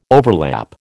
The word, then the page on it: overlap: American English